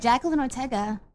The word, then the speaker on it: Jacqueline Ortega: Jacqueline Ortega.